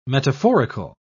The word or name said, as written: metaphorical